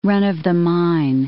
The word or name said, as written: run-of-the-mine